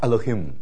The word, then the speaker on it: Elohim: 所以第一个字读得很快，Elohim 啊，第一个音很短，Elohim。